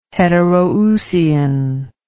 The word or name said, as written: heteroousian